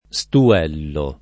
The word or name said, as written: stuellare